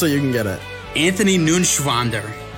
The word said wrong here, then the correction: Neuenschwander: Ah, you didn't pronounce it. Right. An- Anthony. Anthony Nunschwander. It's, it's N E U E N. It's Neuenschwander. Anthony Neuenschwander.